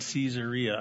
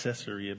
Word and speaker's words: Caesarea: He was sharing the gospel with the Ethiopian and teaching, and he had settled in Caesarea. I used to call it Caesarea, but then I heard some Jew pronounce it Caesarea, which makes sense because it's named after Caesar.